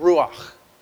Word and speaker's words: ruach: And it's this fun Hebrew word that I don't know if I have a robust enough throat to say, but it's this word ruach. And I think you get really guttural when you say it correctly.